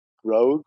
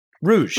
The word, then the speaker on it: Rouge: Ganning, R-O-U. – R-O-U. Air Canada. Oh, I don't know how to say this last word. Is it Rogue? Rouge? – Rouge. Rouge. Very good.